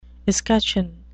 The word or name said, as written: escutcheon